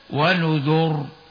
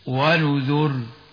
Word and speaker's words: ونذر: كما نطقنا، وهناك كلمة ونذر في سورة القمر في ستة مواضع، قال العلماء يجوز تفخيم الراء ويجوز ترقيقها عند الوقف عليها، التفخيم هكذا ونذر والترقيق هكذا ونذر. وقد اختاروا الترقيق لكننا نختار التفخيم عند الوقف هكذا ونذر.